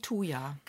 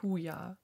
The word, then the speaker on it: Thuja: T-H-U-J-A. Oh, nicht Huja. – T-H-U-J-A. – Thuja. Ja.